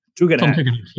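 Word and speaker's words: Tugendhat: Tom Tegenhat, am I pronouncing that one correctly? What's his story? – Tegenhat, yes.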